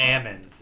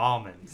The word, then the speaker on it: almonds: If you ever met an Amond farmer, they call them Amonds. They don't call them almonds. – Exactly. – So the L is silent.